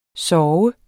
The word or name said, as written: sove